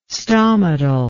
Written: stomatal

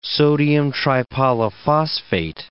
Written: sodium tripolyphosphate

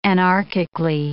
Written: anarchically

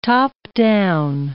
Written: top-down

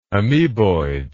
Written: amoeboidism

